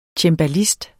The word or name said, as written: cembalist